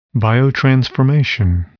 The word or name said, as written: biotransformation